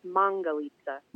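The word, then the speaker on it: Mangalitsa: Well, and I've learned something already because I've always called them Mongolitsas. So now I'll have to correct myself and start saying it correctly with Mongolitsa. – Mongolitsa, yes.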